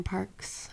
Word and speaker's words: Parkes: But yeah, just look up Erin Parkes. P A R K E S. no one knows how to spell my last name. – Yeah, you got to throw in that silent E.